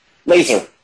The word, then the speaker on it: Lazar: Like, who is Lazar? – Laser. So Laser, sorry. – Yeah. – you know, that's how they say it in Canada. – Yeah, that's how we say it, yeah.